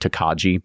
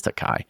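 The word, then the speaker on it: Tokaji: Yeah, I think it's Tokai, because it's like Takaji, but that's not how you say it. It's Tokai.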